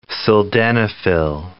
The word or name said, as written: sildenafil